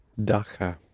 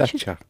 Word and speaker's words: дача: Dacha. – Not quite. – Dasha? – Ч, yes, the дача. – Дача. It's the word дача. And you do spell it in Russian with the letter ч.